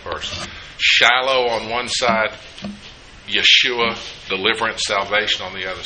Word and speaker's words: Yeshua: It's Yeshua, which we would call Joshua, but Yeshua. Y-E-S-H-U-A is the way we would probably spell it if we were going to do this.